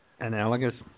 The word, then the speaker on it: analogous: analogous. I think I know what it means, but I don't know how to pronounce it. How is it? – Analogous. – Analogous.